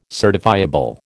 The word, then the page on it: certifiable: American English